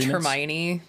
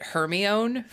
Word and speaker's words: Hermione: I pr- I pronounced it Hermione for years until the movie came out and then I realized that it was Hermione. But I I literally thought it was Hermione for because like that's what happens when you're a reader is you figure out how to pronounce it based on what it looks like on the page.